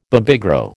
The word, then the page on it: Babygro: American English